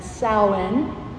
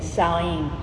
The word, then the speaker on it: Samhain: The 3 days, the 31st, 1st, and 2nd, are the festival that is pronounced in Ireland and on the Isle of Man as Samhain, and pronounced either Samhain or Samhain in Scotland. And since the majority of my ethnic heritage is Scottish, I'm going to say Samhain.